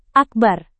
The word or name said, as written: akbar